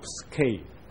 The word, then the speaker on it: psyche: psyche 这个字在希腊文里面其实读作 psyche，但是拼成 psuche 啊，那再加上 logos 这个字，就变成心理学或者精神医学 psychology。